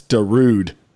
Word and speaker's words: Darude: A Darudian sandstorm, you mean? Dude, I was seeing that earlier today. – Do you know what Darudi sandstorm is? – It's Darude.